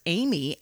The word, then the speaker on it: AMI: It's an AMI model that Tarantino affectionately pronounces as Amy, and it is Quentin Tarantino's personal jukebox that was trucked to Austin to be used in the film.